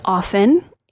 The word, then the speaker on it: often: It is often, the no T in the dictionary, or sorry, it's often in the dictionary. The T is silent. Why do they bother putting in letters in words if they are supposed to remain silent?